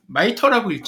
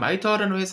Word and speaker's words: MITRE: MITRE 마이터라고 읽죠? 미국에서요. – 네, 마이터입니다. – 네, 마이터. 예. 여기로 이직하셨는데 일단 마이터라는 회사는 어떤 일을 하는 회사인지 말씀해 주시죠.